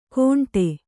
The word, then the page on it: ಘೋಂಟೆ: Kannada